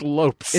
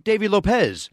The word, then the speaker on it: Lopes: It's spelled Lopes and it's pronounced Lopes. – It's Lopes, but when I moved here, a lot of people said, you know, the great Davey Lopez. And I was it's never been Davey Lopez.